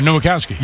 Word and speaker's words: Nowakowski: I want to make sure, Nowakowski. Nowakowski. – Yeah, Nowakowski. – You sound like you said Nowakowski. – Oh, yeah. I didn't want to leave out an extra syllable there.